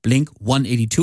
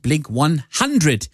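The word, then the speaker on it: Blink-182: Und die heißen ja eben Blink 182 und nicht Blink 182, weil sie sich für die britische Aussprache entschieden haben, weil das so schön klingt, sagen die Jungs aus San Diego, USA.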